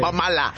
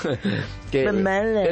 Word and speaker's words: Bob Marley: ¿Cómo es la peor manera de pronunciar Bob Marley? – Bob Mala. – Bob Mala. – Bob Marley. Es la jamaiquina.